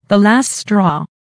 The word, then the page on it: the last straw: American English